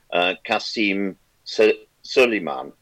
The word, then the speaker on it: Qasem Soleimani: Well, I have always understood and I've been aware of him for many years now, Qasem Soleimani. – Yeah, because I've heard it pronounced at least four different ways on broadcast outlets. – They're now putting an I on the end, which I find strange.